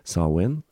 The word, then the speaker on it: Samhain: Sawin, som eh, skrives Samhain, men som jeg forsøker å uttale på gallisk som best jeg kan,